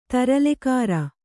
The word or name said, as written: ತರಲೆಕಾರ